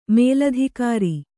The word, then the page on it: ಮೇಲಧಿಕಾರಿ: mēladhikāri